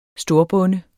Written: storbonde

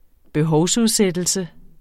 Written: behovsudsættelse

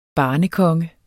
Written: barnekonge

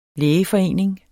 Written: lægeforening

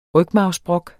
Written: rygmarvsbrok

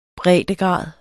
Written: breddegrad

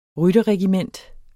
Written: rytterregiment

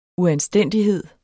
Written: uanstændighed

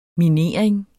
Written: minering

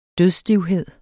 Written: dødsstivhed

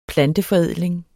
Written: planteforædling

